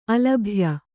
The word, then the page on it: ಅಲಭ್ಯ: alabhya